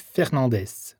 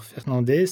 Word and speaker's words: Fernandez: Morvan, ça s'écrit M-O-R-V-A-N. – Eh bien moi, c'est André Lorieux. L-O-R-I-E-U-X. – Alors moi, je m'appelle Philippe Fernandez. Alors Fernandez, c'est F-E-R-N-A-N-D-E-Z. – Bonjour, alors Céline Tribalier. Et Tribalier, ça s'écrit T-R-I-B-A-2-L-I-E-R.